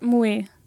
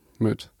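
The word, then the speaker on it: Moët: meint Mue. – Nee, so wird es nicht ausgesprochen. Möd. Möd. Ähm, Möd, genau, man trinkt dann immer Möd.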